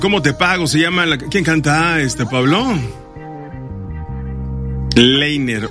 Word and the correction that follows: Lenier: se escribe Liner, ¿no? Ah, Lenier. Bueno, pues ahí está.